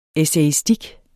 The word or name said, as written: essayistik